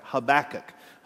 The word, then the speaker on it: Habakkuk: That's right, I said it right, Habakkuk.